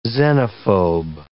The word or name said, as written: xenophobe